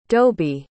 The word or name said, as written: dobie